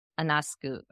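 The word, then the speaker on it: Ionescu: Anascu.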